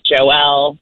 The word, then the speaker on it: Joelle: it's like the first Joelle, the first Noel.